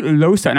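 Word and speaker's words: Llosa: It's Louis Losa. – Louis Losa. – I could be saying that wrong, but no, I think that's right. It's the double L on the Losa.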